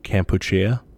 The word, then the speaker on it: Kampuchea: So I learned that this was like the Khmer pronunciation of it, or the native pronunciation of it is Kampuchea.